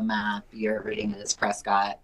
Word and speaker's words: Prescott: So, because if you pronounce the O, you're going to spit it all out. So, that's allegedly mm-hmm. why it's called Prescott, and it's also just easier off the tongue, I guess.